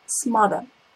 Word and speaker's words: smother: First word I want you to pay attention to is smother. It is almost like mother. So the example for you to remember the pronunciation of this word is some mothers smother their children with too much care.